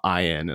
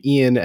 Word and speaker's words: Iain: Ian pronounced Ian, not Ian, as I thought it was for a long time.